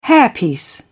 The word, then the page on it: hairpiece: British English